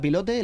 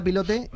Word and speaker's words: Pilote: Hasta que en 1959 eh, se funda la revista Pilote, la revista Pilote, que seguro que no se dice así. pilote Pilot. Eso es pues pero se decía pilote.